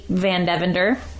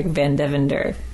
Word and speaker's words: Van Devender: My name is Kate Van Devender.